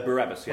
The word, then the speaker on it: Barabbas: Whither of them will ye have, Christ Jesus or Barabban? – I think it must be Barabbas. – No, Barabbas, yes. Barabbas. Yes, it's just, for some reason it's spelled that way. Just it's presumably to make it rhyme.